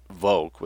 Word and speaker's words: Volk: He used the term folk, or Volk with a V, which is pronounced as an F in German, to get at the idea that he was referring to a people who share a biological inheritance and a way of being.